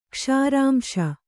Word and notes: ಕ್ಷಾರಾಂಶ: Kannada

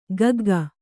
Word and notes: ಗಗ್ಗ: gagga

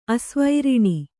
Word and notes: ಆಶಾವಾರಣ: āśāvāraṇa

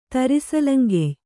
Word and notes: ತರಿಸಲಂಗೆಯ್: kannada

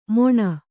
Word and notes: ಮೊಣ: Kannada

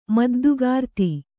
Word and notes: ಮದ್ದುಗಾರ್ತಿ: Kannada

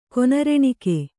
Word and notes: ಕೊನರೆಣಿಕೆ: Kannada

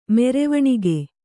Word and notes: ಮೆರೆವಣಿಗೆ: merevaṇige

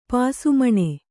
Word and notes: ಪಾಸುಮಣೆ: pāsu maṇe

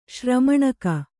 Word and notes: ಶ್ರಮಣಕ: Kannada

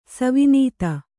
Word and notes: ಸವಿನೀತ: savinīta

